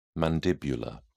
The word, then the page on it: mandibular: British English